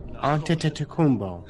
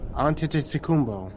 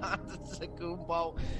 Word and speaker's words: Antetokounmpo: T-E-T, Ante, hold on, A-N-T-T-E-N-T-T, U, Ante, C-U-M-P-O, Antetokounmpo. – No, it's pronounced that again. – Antetokounmpo. – No, it is not Antetokounmpo.